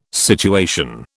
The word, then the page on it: situation: British English